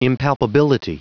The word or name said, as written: impalpability